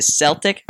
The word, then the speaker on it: Celtic: the young Americans, Mark McKenzie for the Philadelphia Union, looks to be on a move to Celtic, how it's pronounced there.